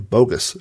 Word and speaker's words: Bogas: Well, I guess it's pronounced the same, Bogus. His last name is B-O-G-A-S, but it says also sometimes spelled B-O-G-U-S, which would be Bogus.